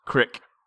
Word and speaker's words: creek: He reached a large stream, of the kind the locals called a creek, and pronounced Crick, and decided to follow it.